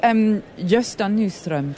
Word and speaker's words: Gösta Nyström: So this is Gösta Nyström. Did I pronounce that correctly? – Perfectly.